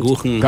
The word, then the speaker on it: Kuchen: Café Kuchen.